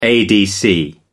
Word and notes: adc: American English

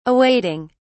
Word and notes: awaiting: British English

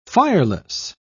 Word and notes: fireless: British English

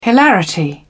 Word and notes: hilarity: British English

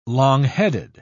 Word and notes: long-headed: British English

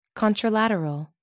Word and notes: contralateral: American English

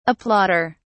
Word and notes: applauder: British English

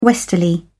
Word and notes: westerly: British English

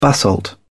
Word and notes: basalt: British English

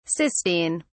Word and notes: cystine: English; British English

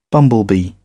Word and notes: bumblebee: British English

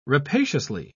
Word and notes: rapaciously: British English

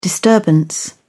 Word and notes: disturbance: British English

